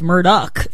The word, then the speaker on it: Murdaugh: And it's really How to Get Away with Murdaugh, if you go by their own pronunciation.